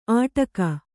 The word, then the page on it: ಆಟಕ: Kannada